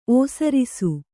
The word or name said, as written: ಒಸರಿಸು